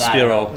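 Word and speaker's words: Spirou: When you said Spyro, I thought of the French comic. Which is pronounced Spiro.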